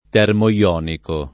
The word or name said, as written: termoionico